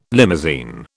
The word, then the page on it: limousine: British English